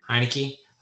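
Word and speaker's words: Heinicke: But I'm playing against Taylor Heineke, who, how do you say his name? – Heineke.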